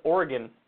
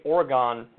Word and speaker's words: Oregon: Another one was Oregon. I used to say Oregon, and people were like, you don't say Oregon, you say Oregon. You gotta say Oregon.